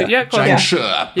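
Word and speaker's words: Jiangshi: Or I guess that's somewhere around $65,000 for, and I've got to get the pronunciation correct, is it a Jiangshu? – Jiangshu. – Blood in the Blanket Hall. Close to. – Jiangshu. – Yeah. There you go, that's better.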